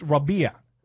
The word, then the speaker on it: Rabe: My name on my birth certificate, on my license, is spelled R-A-B-E. It's not even pronounced like broccoli, Rob. It's an Arabic word for springtime, like spring, summer, fall, winter. My dad named me after spring, and R-A-B-E is pronounced Rabia.